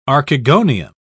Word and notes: archegonium: British English

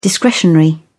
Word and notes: discretionary: British English

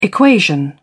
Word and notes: equation: British English

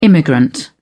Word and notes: immigrant: British English

